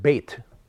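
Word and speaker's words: Beit (בית): When you go to the Gesenius lexicon, not Strong's Concordance, and you take a look at the word Beit, that's how it's pronounced.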